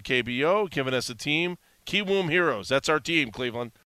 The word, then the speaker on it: Kiwoom Heroes: Okay, Kiwoom Heroes, right? Did I say that right? – Yeah, Kiwoom Heroes.